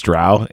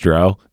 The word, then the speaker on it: Drow: If you notice too, he says Drow because I told him that because everyone says Drow. And I'm like, dude, it's actually pronounced Drow.